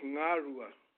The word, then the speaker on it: Ngarua: You pronounce it Ngarua.